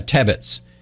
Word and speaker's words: Tebbetts: You know, my name is spelled T-E-B-B-E-T-T-S. There's a lot of Tibbetses, but I'm a Tebbets.